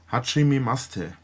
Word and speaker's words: Hajimemashite: Also, die Silben sind Ha-Ji-Me-Ma-Shi-Te. Und das I bei Ma-Shi-Te wird oft verschluckt, also Hajime-Mashite.